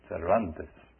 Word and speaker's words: Cervantes: Those of us who speak Spanish, excuse me for saying his name that way. The correct way to say his name is Cervantes.